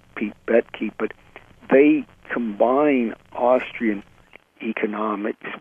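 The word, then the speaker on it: Boettke: Is that how I pronounce the name? – Yes, Betke.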